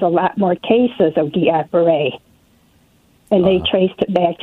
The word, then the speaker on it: Guillain-Barré: Yeah, I had Guillain-Barré. That's how you pronounce it.